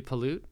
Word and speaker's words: pollute: So, but it teaches you not to pollute, because if you pollute, they'll come kill you. – I believe it's pronounced pollute. – Pollute. It is how you deposit your pollen.